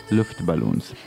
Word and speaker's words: Luftballons: Luftballons, che vuol dire palloncini.